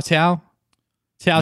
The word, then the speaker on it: Cao Cao: And then we also see this guy who will become a bigger character later, his character Zhao Zhao. Sao Sao. Sao Sao? Tiao Tiao? – Tiao Tiao. Yeah. Which is spelled C-A-O-C-A-O.